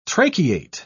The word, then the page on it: tracheate: British English